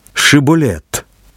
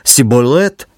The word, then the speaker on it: шиболет: Усім було відомо, що єфремляни не могли вимовити звук «ша». Замість нього вони вимовляли «с». Тому коли до переправи наближався який-небудь вояк, сторожа наказувала йому «Скажи шиболет». Це слово означає «зерновий колос», але мав значення не зміст, а те, як це слово буде вимовлено. Якщо наляканий розгублений солдат казав «сиболет», то сторожа знала, що це ворог. Якщо ж воїн казав «шиболет», йому дружньо кивали і дозволяли переправитись.